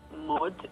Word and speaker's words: Maud: Então, eu tenho o meu Instagram, que é arroba Maria Mod. Mod se pronuncia, né? Mas se escreve M-A-U-D. Então, assim, Maud, né?